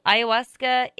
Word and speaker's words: ayahuasca: it definitely is pronounced like a Spanish word. So, ayahuasca is A Y A H U A S C A ayahuasca.